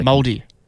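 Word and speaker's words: Māori: Say moldy boys? – Like, moldy. – What does that mean? – Like Maori. – Oh, yeah. – That's how you pronounce it.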